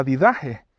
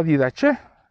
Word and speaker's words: Didache: Y estoy hablando del documento que se llama la didaje o la didache. Hay personas que lo pronuncian de diferentes maneras.